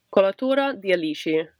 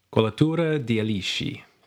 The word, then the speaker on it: Colatura di Alici: Sorry, give me that pronunciation one more time. – Colatura di Alici. – Colatura di Alici.